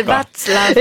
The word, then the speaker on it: Václav: Il y a pas Václav, c'est terminé, c'est Václav.